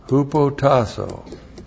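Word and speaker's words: hupotassō: Hupotasso. H-U-P-O-T-A-S-S, long O.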